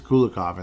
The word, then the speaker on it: Kulikov: I keep saying it wrong. There's no H. There's no H. Why am I saying H? It is Kulikov in this case.